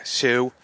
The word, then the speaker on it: Hsu: At the end of the night, at the end of the rally, we're back with the co-founder, Ben Hsu. Am I pronouncing that right? I've been waiting my entire life to ask you this. – Just like a shoe you wear on your foot.